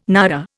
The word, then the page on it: naira: Indian